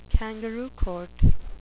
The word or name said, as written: kangaroo court